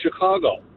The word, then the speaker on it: Chicago: It's properly pronounced Chicago. So when someone says Chicago, you can tell right away they're not from there.